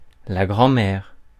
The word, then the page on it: grand-mère: [la ɡʁɑ̃.mɛʁ]; France (Paris)